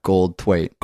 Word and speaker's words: Goldthwait: That's how you pronounce that, Goldthwait. – Goldthwait.